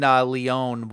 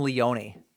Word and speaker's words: Leone: Damien Leone was on, which I always called him Leone. It's Leone. The E is silent.